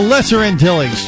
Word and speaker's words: Lesser Antilles: it's the Lesser Antilles chain.